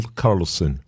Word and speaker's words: Karlsen: His name is spelled E-M-I-L, first name, Emil, and then K-A-R-L-S-E-N, Emil Karlsson.